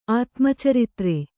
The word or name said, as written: ಆತ್ಮಚರಿತ್ರೆ